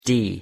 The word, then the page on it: d: English